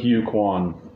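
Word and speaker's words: Ke Huy Quan: Ki-Hu Kwan.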